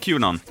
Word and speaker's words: QAnon: How do you pronounce it? QAnon? – QAnon.